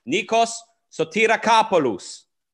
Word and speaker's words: Nikos Sotirakopoulos: Nikos Sotirakopoulos.